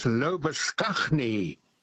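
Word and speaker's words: Labuschagne: Lobashane. – Oh, yes. – Now, Mr. Lobashane's name in South Africa, where I spent my youth, was and still is pronounced as Lobaskagni.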